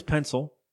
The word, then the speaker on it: Pensyl: So the guy is, his last name is Pencil, in apartment number 2 on Proctor Street. That's right. Number 2 Pencil on Proctor Street. – It's spelled like P-E-N-S-Y-L. – As in Pennsylvania.